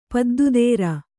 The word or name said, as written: ಪದ್ದುದೇರ